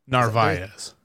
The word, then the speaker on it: Narvaez: Narvaez.